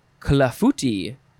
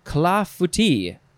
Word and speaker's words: clafouti: Next is clafouti. No, that's not how you pronounce it. You say, I think that's the, I emphasize the wrong parts. Clafouti, clafouti. I believe that's correct. It is spelled C-L-A-F-O-U-T-I, or you can put an S at the end.